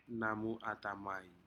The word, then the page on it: namu atamai: Aneityum